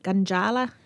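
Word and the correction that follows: Gudjala: country? – Gujala. – Gujala. Yeah, Gujala.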